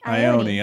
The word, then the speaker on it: Ione: We got John Mahoney, Ion Sky, John Cusack. – Ion Sky? Is she a video game? – How do you say it? – Ione. – Ione Sky, John Cusack, and Say Anything.